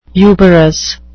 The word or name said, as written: uberous